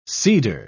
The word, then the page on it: seeder: British English